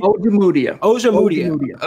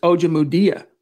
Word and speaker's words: Ojemudia: The quarterback they drafted, Amuja. What's his name? How do you pronounce his name? – Ojemudia. – Okay, I always mispronounce it.